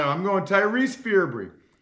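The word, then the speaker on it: Fearbry: I would say Ferbree. It's F-E-A-R-B-R-Y, right? So I'm going fear. If it's F-E-A-R, I'm going Ferbree, B-R-Y.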